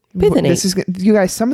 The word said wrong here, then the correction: phthalate: Diethyl phthalate. Phthalate. I think the P is silent. Phthalate. – Phthalate.